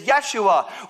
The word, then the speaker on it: Yeshua: And the reason that they had that expectation is because if you, uh, if you understand uh, how Jesus' name actually uh, is pronounced in its original context, it is Yeshua, which means Joshua, which means what they were expecting was a warrior to walk, to ride into the city.